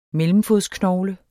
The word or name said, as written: mellemfodsknogle